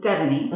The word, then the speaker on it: Devanie: So we are here on the podcast with Devanie.